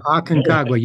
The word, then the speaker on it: Aconcagua: In 2011, he joined a team to climb the tallest mountain in South America, Mount Aconcagua. Am I butchering that, or is that correct? All right, thank you very much. – Right on the money. Aconcagua, you got it, buddy.